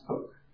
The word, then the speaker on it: Coke: Okay, so Edward Cook, spelled like Coke, pronounced Cook.